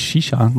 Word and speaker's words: Saoirse: Die Hauptdarstellerin wird gespielt von Saoirse Ronan, die ist nämlich auch eine Irin und dieser Vorname, der sich schreibt Saoirse, der spricht sich Saoirse, musste ich dort erfahren.